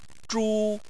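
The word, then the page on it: zhu: tone 1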